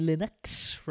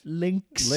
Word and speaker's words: Lynx: You owned it already on the Atari Linux, right? – Linux? – Lynx.